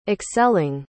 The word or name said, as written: excelling